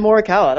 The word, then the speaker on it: Morikawa: Evan Morikawa.